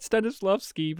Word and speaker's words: Stanislavski: He mispronounces it several times. – yeah, you said it right, Stanislavski, but he's he calls him Stanislavski or something stupid. He adds like an extra something on the end.